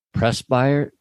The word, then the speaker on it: presbyter: In Eastern Orthodox Church, St. Valentine is recognized on July 6th, in which St. Valentine, the Roman presbyter.